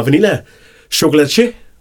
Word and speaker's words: Vanilla Chocolatier: Jeg har fået besøg i studiet af Jeanette fra Vanilla Chocolatier og konditori hedder det? Det var meget flot udtalt.